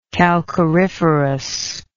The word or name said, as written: calcariferous